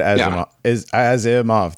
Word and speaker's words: Asimov: I've always said Asimov, three syllables.